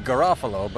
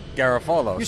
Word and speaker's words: Garofalo: It's supposed to be Garofalo, but we say Garofalo. – Serious? – Yeah, I mean, that's the Italian translation, and somewhere on the boat, we changed it.